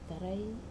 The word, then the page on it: tarai: Wamesa